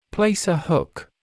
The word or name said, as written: place a hook